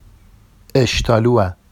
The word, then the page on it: eʃtɑlua: Vafsi